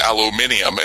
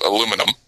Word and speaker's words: aluminium: because over in England they pronounce it aluminium instead of aluminum.